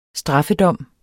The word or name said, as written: STRAFFEDOMS